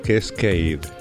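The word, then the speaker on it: Kaskade: Y se llama Cascade, Cascade con K. Es una difícil pronunciación, Cascade. Y este tema, Honesty, me lo encontré en un álbum recopilatorio en el volumen 3 de Hemisphere.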